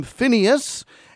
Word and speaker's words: Phinehas: And it's called in Hebrew Pinchas, the name of a person. Your English Bible in the book of Numbers might call him Phinehas. And so that's the two different names, the English pronunciation or the Hebrew pronunciation.